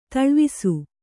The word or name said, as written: ತೋಳ್ವಾಸ